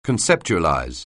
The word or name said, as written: conceptualize